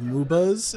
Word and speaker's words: MOBA: I'm interested in knowing a little bit more about the formation of the game in and of itself, the idea of MOBAs. Is that how you pronounce it? – Yeah, MOBA, yeah.